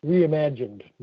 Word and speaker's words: reimagined: The ceremony's footprint, okay, will be extremely limited, and the parade that follows will be reimaged. What's that mean? What does that exactly mean? – Reimagined, not reimaged. There's another syllable in there.